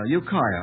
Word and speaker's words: Ukiah: Jesse Duell of Ukiah, I hope I'm pronouncing that correctly, California. I certainly am pronouncing that correctly. Ukiah, I'm informed.